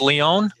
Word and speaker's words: Lyon: Is that how you pronounce it in French? Lyon? – It's a very good pronunciation.